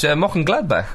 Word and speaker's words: Mönchengladbach: Mönchengladbach went to Leverkusen and won 6-3. – Good pronunciation there. – Yeah, thanks. Or Mönchengladbach. – I would say Mönchengladbach.